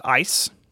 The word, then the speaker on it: EIS: It's EIS, but pronounced ICE.